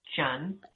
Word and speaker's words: Tran: Most people say Tran, but actually the Vietnamese pronunciation is Chan.